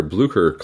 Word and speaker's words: Blücher: The popular Blücher class cruiser, as I recently found out it's supposed to be pronounced, dropped 4 points.